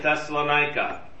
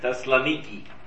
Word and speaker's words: Thessaloniki: And in Macedonia, there were two major cities, Philippi and Thessalonica. Or as we learned on our Greece trip, Thessaloniki. Right? That's how they would pronounce it in Greek.